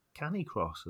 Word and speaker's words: Canicross: And um, thanks ever so much for coming on and telling us all about Canicross, as I now know it's pronounced correctly.